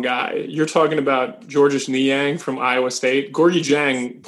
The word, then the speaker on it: Niang: Gorgui Dang. I'm not Dang. I'm saying Niang.